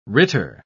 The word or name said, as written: ritter